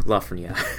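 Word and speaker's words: Lafrenière: I hate to sound pretentious, but I have to pronounce it the French way. Lafreniere.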